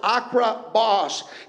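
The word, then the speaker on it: akrabos (ἀκριβῶς): It's translated from a Greek word pronounced akrabos, and it means literally to walk carefully, to walk diligently, accurately, or precisely.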